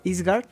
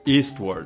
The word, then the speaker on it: Eastward: Pero bueno, mi Dani, no, yo sigo jugando Eastward, Eastward Eastgard, ¿cómo se, pronuncia? – Eastward. – Ah, Eastward. Ya, ya llegó por acá el Master de Master, Joel Rojas.